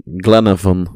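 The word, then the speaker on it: Glenavon: 329 dagen, weet jij mij dan te vertellen, die zijn debuut maakte voor ja, Glenheaven spreek je uit, denk ik. – Glenhaven Oh, kijk.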